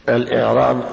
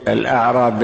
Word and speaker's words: الإعراب: الإعراب بكسر الهمزة ليخرج الأعراب بهذه الهمزة وهم البوادي الذين يسكنون البراري.